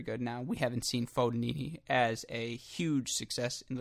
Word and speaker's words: Fognini: I was told that it's very Midwest of me to say Fognini, that it's Fognini, that the G is silent.